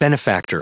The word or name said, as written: benefactor